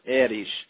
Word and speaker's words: Erich: It's E-R-I-C-H. It's actually pronounced Erich. But not too many people in the States would call me Erich, so I go by Eric.